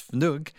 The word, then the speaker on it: Fnugg: It's Fnugg. F-N-U-G-G, which means something like a snowflake or something small.